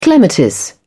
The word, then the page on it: clematis: British English